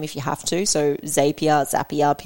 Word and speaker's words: Zapier: It's Zapier. Like, this is how you say our name.